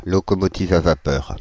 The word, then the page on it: locomotive à vapeur: [lo.ko.mɔ.ti.v‿a va.pœʁ]; /lɔ.kɔ.mɔ.ti.v‿a va.pœʁ/